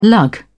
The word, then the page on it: lug: British English